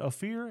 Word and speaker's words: Ophir: I said, how was it pronounced, Ryan? Ophir? And he said, yep, that's it.